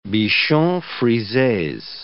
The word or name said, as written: bichons frises